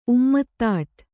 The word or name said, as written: ಉಮ್ಮತ್ತಾಟ್